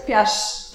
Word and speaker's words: Kpiarz: Kpiarz.